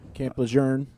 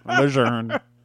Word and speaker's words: Lejeune: Camp Lejeune. – Camp Lejeune. – You're right. You're right. Lejeune. – It's French. The R is silent.